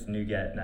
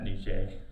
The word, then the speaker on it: NuGet: Like, I'm fine with Nubkeg, but I think it's Nuget, not Niger.